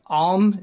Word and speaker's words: Amn: The merchant kingdoms of, I'm going to say this is pronounced Amn. It's just A-M-N. – That sounds about right. – That sounds right.